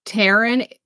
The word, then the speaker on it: Taron: Taron. It's T-A and then Iron.